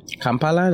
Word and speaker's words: Kampala: It's about a 25-minute drive to the capital city of Kampala. – Yes. – How is that pronunciation? – Kampala, that's perfect.